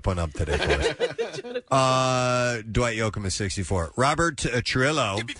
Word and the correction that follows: Trujillo: Which I think the actual pronunciation of his name is Robert Trujillo. – Really? – Because it's spelled T-R-U-J-I-L-L-O, and the double L in Spanish is kind of a Y.